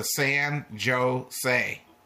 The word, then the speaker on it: San Jose: One said he had been to San Jose. The other said, Don't you know that the J is pronounced like an H?